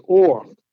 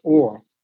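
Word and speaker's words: or (עור): Now, the Hebrew word for skin is or. Okay. Uh-huh. That's how it's pronounced, or.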